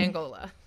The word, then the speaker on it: Angola: Although, who knows how they pronounce it in the South? They probably say Angola or whatever. They probably do. I have no idea. None whatsoever. – But the country is pronounced Angola.